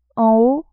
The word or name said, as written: en haut